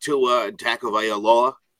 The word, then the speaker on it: Tua Tagovailoa: At number five, the Miami Dolphins did take Tua Tagovailoa, which...